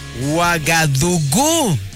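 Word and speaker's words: Uagadugú: Uagadugu. Tiene el acento en la U.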